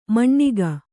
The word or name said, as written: ಮಣ್ಣಿಗ